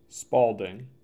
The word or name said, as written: Spaulding